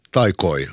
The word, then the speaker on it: Taikoi: Först i dessa tre i rad hörde vi Vilma Jää, Lemmen Nostajan Ämmä, albumet Loitsui ja Taikui. Taikoi, ursäkta.